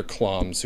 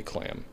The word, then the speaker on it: Klamm: There are people in the Midwest, though, other Kloms who say clam. But I refuse. The German is Klom, so I'm stick with that.